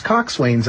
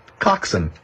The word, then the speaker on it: coxswain: But he popped the balloon here with his last sentence where he says, "But I prefer the more common pronunciation of coxswain, which is coxswain." So there I was going through the whole thing, mispronouncing the term. It's coxswain, like a bosun, or, you know, all these Navy terms, they kind of shorten them.